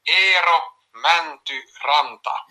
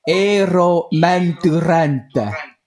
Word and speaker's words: Eero Mäntyranta: Eero Mäntyranta. – Eero Mäntyranta. – he's just confirming it there for you. – Eero Mäntyranta.